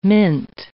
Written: mint

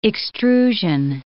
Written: extrusion

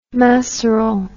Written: maceral

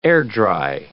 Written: air-dry

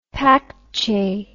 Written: paekche

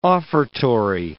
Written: offertory

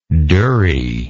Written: durrie

